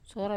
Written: sora

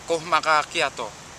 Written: kohmaka kiato

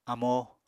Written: amo

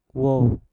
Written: wob

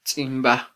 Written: tzimba

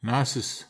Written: nases

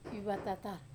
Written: vevatatar

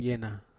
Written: ena

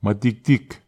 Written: mwatiktik

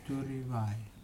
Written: turivai

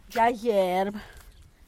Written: gyagyerb

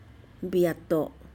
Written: biet'o

